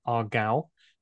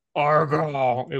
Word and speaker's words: Aargau: The first pronunciation I got of that was Aargau. It was really quite aggressive. And I found another one which said it was Aargau.